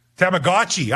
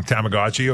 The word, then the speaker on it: Tamagotchi: Tamaguchis. Jonah and Swift Current. What are Tamaguchis? Adam, do you know what those things are? Tamagotchi, I'm saying. Okay, so they're Tamagotchi. I'm saying it wrong. Tamagotchi, okay.